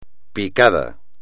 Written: picada

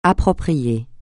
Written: approprié

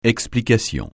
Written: explication

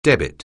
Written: debit

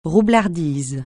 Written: roublardise

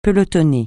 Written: pelotonner

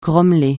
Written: grommeler

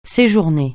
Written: séjourner

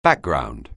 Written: background

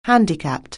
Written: handicap

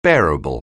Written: bearable

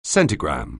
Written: centigram(me)

